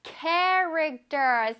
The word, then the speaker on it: character: Character. 쌤 입모양 보세요. Character. Character. 그쵸? 앞에 CH가 있다고 해서 추 소리가 나지 않아?